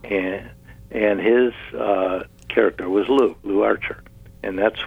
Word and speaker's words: Lew: And she's very caring and very loving about her son, Lou, which is spelled L-E-W, which I thought, well, you know.